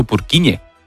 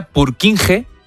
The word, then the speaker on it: Purkinje: Es que se escribe, portugués. En español sería Purkinje. – Purkinje. – Pero Purkinje es en checo, que es porque es el apellido del descubridor.